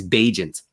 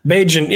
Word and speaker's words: Bijan: It's Bijan. – Man, what an awful pronunciation. But Bijan, yeah, as you can see, I was listening to the game real close.